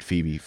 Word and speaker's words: Phoebe: Right, Phoebe.